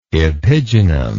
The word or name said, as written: epigynum